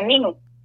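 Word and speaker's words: Nîmes: para nosotros la, pronunciación sería Nimes, pero se escribe Nimes.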